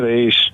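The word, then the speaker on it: reis: Ότι μπορούμε να δούμε να συνοδεύουν το Roots Race. Εγώ το λέω με την τουρκική προφορά.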